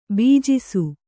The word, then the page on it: ಭುಜಿಸು: bhujisu